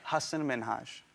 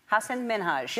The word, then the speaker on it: Hasan Minhaj: People always mispronounce it. They're always like, Hasan Minhaj, Hussein. – Say it again so that people hear it again. – Hasan Minhaj. – Hasan Minhaj. – Yes, that's it.